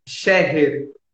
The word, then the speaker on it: Scherrer: Scherrer.